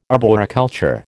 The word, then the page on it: arboriculture: American English